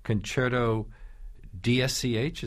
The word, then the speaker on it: Concerto DSCH: and I'm not sure how to pronounce it, Concerto DSCH, is that how it's said? – Yeah, a great work by Alex Rodmansky.